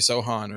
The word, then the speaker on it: Sochan: Did I say his name right, Dave? You looked at me like I didn't say it right. – Jeremy Sochan. – Sochan, thank you. – Yeah, you're right.